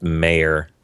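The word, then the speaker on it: Meijer: I said on my show, I called them Major Market because out here we do have a market called Major, M-A-J-O-R. – Yeah. – They're M-E-I... – J-E-R or something like that? J-E-R. – M-E-I-J-E-R, I think. – And I think it's pronounced Mayor. – Yeah, that makes sense.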